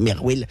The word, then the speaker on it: MRWL: Du coup, moi au début, que je connaissais pas trop Marwal, je me dis mais comment on prononce ça? En fait, c'est juste on peut le prononcer Merwil.